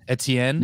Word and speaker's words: Etienne: And one of the funniest things that could possibly happen in sports betting happened last week in the NFL, which was Travis, how do you say his name, the running back, Etienne? – Mm-hmm. Yeah, Etienne.